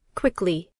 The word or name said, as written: fast